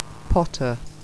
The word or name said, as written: potter